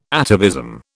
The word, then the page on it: atavism: British English